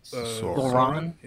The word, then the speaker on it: Sauron: Well, it almost looks like the Sorin tower. That's not his name. How do you say the guy's name? – Sauron.